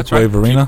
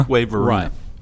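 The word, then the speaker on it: Fuquay-Varina: Yeah, Fuquay Verena. – Fuquay Verena.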